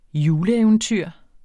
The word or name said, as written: juleeventyr